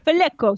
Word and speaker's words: Vallejo: They've brought in this 22-year-old Jesus Vallejo, hopefully I'm saying that right. He's on loan from Real Madrid. Vallejo, see, you can say it.